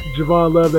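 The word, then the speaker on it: Javon Luv: You can follow follow me at Javon Love. That's J-A-V-O-N-L-U-V.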